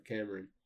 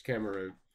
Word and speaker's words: Cameroon: Cameroon. You got much to say about Cameroon? – No. – What about Cameron? – Cameron. – This is just the African version of Cameron. It's Cameroon.